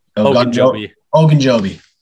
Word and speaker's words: Ogunjobi: Ogunjobi.